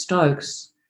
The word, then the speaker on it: Stokes: And it's called Stocks Patterns because my last name is Stokes. And people in Germany can't pronounce it. So it's sort of like Stockers or the Storks or Steaks. Yeah, anyway, so S-T-O-K-X.